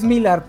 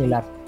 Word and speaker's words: Millar: Sí, es Millar, pero sé que odia que le digan Millar porque es la pronunciación gringa. Entonces, Mark Millar.